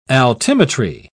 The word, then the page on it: altimetry: British English